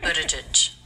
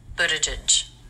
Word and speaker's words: Buttigieg: The T is a flap T, which is normal for a T between vowels in American English. It sounds like the D. Buttigieg. And the G, is it a hard G like go or a soft G like judge? – Pete Buttigieg.